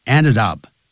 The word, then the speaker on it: ended: Ended up, end 加 ed 念成 ended up, 后头要用放分词 failing the test.